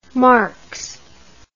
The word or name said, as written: Marquez